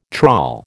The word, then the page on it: trawl: American English